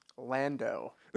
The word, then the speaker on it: Lando: And it's a big win for, what did you say his name? Landon? – Lando. – Lando.